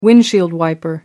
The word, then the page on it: windshield wiper: British English